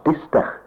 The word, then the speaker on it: Destex: On s'écrit Destex, mais ça se prononce Dister.